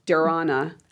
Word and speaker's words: dharana: and then dharasana, I think I'm saying that right, I'm sorry, dharana, which is more about meditation, concentration, and of course, samadhi being enlightenment.